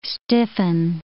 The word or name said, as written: stiffen